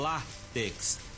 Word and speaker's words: látex: No entanto, na hora da grafia e também da pronúncia, deve-se dar atenção à sílaba tônica lá. É uma palavra paroxítona, pois a sua sílaba tônica é a penúltima, é o látex. E, logicamente, como é uma palavra que termina com X e sendo a penúltima sílaba, tem que ter um acento.